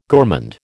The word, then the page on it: gourmand: American English